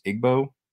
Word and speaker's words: Igbo: I believe it's pronounced Igbo, I-G-B-O, Nigerian heritage.